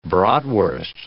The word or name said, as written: bratwurst